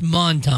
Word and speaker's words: Menton: It's spelled M-E-N-T-O-N, but it's pronounced Monton.